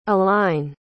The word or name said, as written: alline